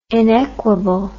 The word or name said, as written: inequable